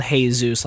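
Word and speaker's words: Jesús: It's spelled Hey Zeus, like hello Zeus, yeah.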